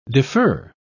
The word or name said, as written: defer